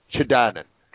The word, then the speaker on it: Chidanand: I'm looking at the message in your book that was written by Swami Chidanand. I'm going to mispronounce his name. Chidanand. – Correct.